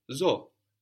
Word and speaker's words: zo: Zo, I live, like in zoo, like in the name in English, Zoe.